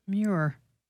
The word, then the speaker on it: Muir: Muir.